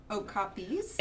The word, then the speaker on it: Okapi: Is the plural Okapis? – It's Okapi. Oh. That is the pronunciation according to the San Diego Zoo.